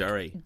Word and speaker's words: Durry: The band Dury. I think I'm saying that right. – Dury? – Brother. – Like Dury. – D-U-R-R-Y? Yes. Like a cigarette? – Correct. Yes.